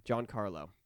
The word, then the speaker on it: Giancarlo: Yeah, Giancarlo.